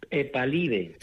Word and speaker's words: Epalive: el epalite, ¿lo he dicho bien? – Epalive. – Epalive, Epalive, con B, perfecto. – Con U. En inglés, Epalive.